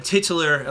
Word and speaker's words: titular: Yeah, the titular lawnmower, how do you say that word? – Titular? – Titular, sorry about that.